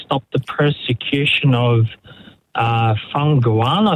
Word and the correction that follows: Falun Gong: I think it's pronounced. – Falun Gong, it'll be.